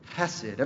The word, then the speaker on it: hesed: hesed. Everyone say hesed. Ready? Hesed. All right, did you spit on the person in front of you? If you said it right.